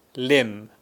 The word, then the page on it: LIMB: British English